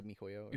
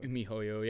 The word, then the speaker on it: miHoYo: It's like MiHoYo. – MiHoYo, yeah.